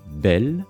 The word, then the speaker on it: bel: Celui-ci se prononce alors aussi belle et s'écrit B-E-L.